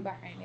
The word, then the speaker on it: Bahrain: am i saying it right bahrain bahrain yeah like you feel you identify with a lot more of that culture than mm-hmm.